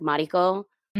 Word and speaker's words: Mariko: Like Mariko, all the like, you know, white people would say like Mariko and like, and I would hate it so much.